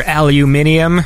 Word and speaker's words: aluminium: But to be fair, you know, whenever people like make fun of people for pronouncing words wrong, like it's spelt aluminum. Like there is no eum at the end. Like, you know, the way like it go aluminium as if there's like an extra vowel in there, which there isn't.